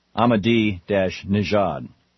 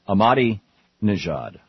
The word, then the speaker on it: Ahmadinejad: Ahmadinejad. Ahmadinejad, I guess, is actually the correct pronunciation.